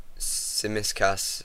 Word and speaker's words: Tsimikas: just correct you. Tsimikas. – Tsimikas. Tsimikas. – It's hard to pronounce when you're not Greek or Cypriot.